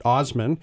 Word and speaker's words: Assman: And then there was a chap in Saskatchewan whose last name looks like Assman, but he pronounces it Osman.